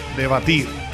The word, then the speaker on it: Dvatir: O sea, se escribe D-V-A-T-I-R, Debatir.